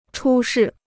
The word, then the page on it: 出事: chū shì